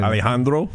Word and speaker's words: Alejandro: Alejandro.